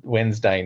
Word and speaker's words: Wednesday: Wed night would be easier than Wednesday night. That's like 4 hours of having a conversation. – Wednesday night. – I listen to this podcast and whenever they say Wednesday, the guy pronounces the D and I'm like, what is going on?